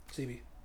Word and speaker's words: Sebi: So, a lot of us also, I don't know if I'm saying his name right, Dr. Sebi? – Sebi.